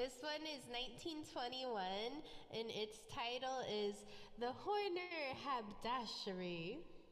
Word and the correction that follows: haberdashery: I said it wrong. It's called the horny haberdashery.